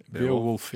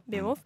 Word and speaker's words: Beowulf: Beowulf, ja. Beowulf.